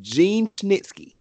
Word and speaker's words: Gene Snitsky: But if we're keeping it a buck, we're saying that in 2019 standards, because me and Corey both remember the days of Gene Snitsky. Did I say it right? – Yes.